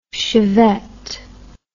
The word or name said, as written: Chevette